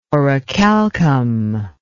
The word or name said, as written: orichalcum